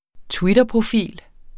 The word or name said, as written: twitterprofil